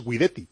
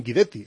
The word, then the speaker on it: Guidetti: No, vamos a ver, no, Pedrito Martín dice que si el apellido Guidetti se pronuncia en italiano es Guidetti. Otra cosa es que como él es sueco, al trasladarse a Suecia él prefiere que le llamen Guidetti.